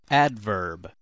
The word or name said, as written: adverb